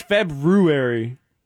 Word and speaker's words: February: Because it's February. There's an R in it. People say February, but it's February. You got to throw in the R.